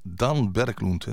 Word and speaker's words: Dan Berglund: En daarvoor uit 2010 Dan Berglund, hè, zo moet je dat uitspreken.